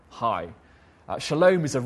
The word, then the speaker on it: shalom: He's talking about the great Old Testament hope of shalom, or shalom, as most people pronounce it, shalom.